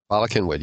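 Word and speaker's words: Bala Cynwyd: How do you pronounce that? Balakinwood? – Balakinwood, yes.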